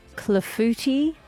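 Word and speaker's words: clafoutis: She's cooked you clafouti. Nailed it. The French cake that I think I've only just pronounced correctly for the first time.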